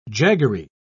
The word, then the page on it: jaggery: British English